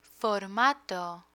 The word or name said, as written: formato